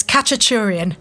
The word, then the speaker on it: Khachaturian: It's Khachaturian.